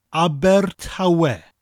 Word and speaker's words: Abertawe: Abertawe. Spelt A-B-E-R, Aber, with that rolling R sound, and Tawe, T-A-W-E.